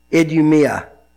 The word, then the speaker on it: Idumea: And I even have this in my Bible, and it's a long U and a long E and a short A. So you're probably right, Marty. Idumea.